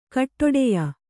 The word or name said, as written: ಖಡ್ಡಾಯ